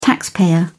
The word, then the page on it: taxpayer: British English